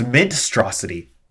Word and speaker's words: Mintstrosity: Wait, say it again. – Minstrosity. – How do you spell that? – M-I-N. – Uh-huh. – Oh, it's Mintstrosity. Mintstrosity. – Oh, it's even better.